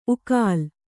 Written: ಉಕ್ಕಲು

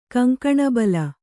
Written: ಕಂಕಣಬಲ

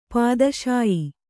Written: ಪಿತ್ತಾಶಯ

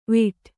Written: ವಿಟ್

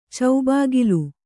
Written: ಚೌಬಾಗಿಲು

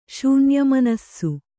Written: ಶೂನ್ಯಮನಸ್ಸು

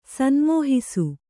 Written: ಸನ್ಮೋಹಿಸು

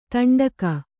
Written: ತಂಡಕ

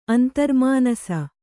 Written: ಅಂತರ್ಮಾನಸ

